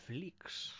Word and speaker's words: flicks: Ok, pero no se escribe con X, lo han estilizado así, pero es F-L-I-C-K-S. Flicks son películas.